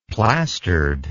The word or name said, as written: plastered